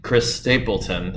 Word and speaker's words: Chris Stapleton: So you're getting back from Chris Stapleton.